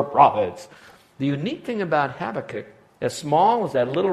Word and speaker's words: Habakkuk: I want you to know about a little known prophet back then by the name of Habakkuk. Even when you pronounce it, it sounds like you're clearing your throat. Say it with me. Habakkuk.